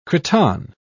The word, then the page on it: cretonne: British English